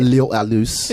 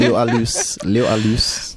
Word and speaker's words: Liv og Lys: How do you say that? – Liv og Lys. – Yeah. – Liv og Lys.